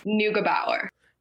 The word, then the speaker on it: Nukenbauer: But Hannah Nukenbaumer? – Okay, you are close. – How do you say it? – Nukenbauer. – Nukenbauer. Okay, and that's German? – Yep, it's German.